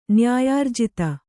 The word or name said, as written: ನ್ಯಾಯಾರ್ಜಿತ